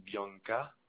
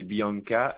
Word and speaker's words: Bianca: Alors, Giardini Bianca. – Ça s'écrit comment? – Alors, Giardini, G-I-A-R-D-I-N-I. – Oui. – Et Bianca, B-I-A-N-C-A.